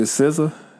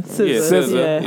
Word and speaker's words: SZA: All right, now I'm gonna admit I'm not familiar with the SZA. That's how you say her name. – SZA.